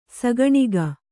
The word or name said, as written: ಸಾಕಾಣಿಕೆ